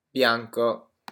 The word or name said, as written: bianco